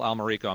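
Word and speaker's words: Almerico: Well, if you Google the name Kendall Almirico, I'm the only one in the entire world, but my email...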